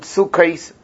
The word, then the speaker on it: sukkos: You pronounce it sukkos, plural. So therefore he says, sukkos, sukkos, sukkos.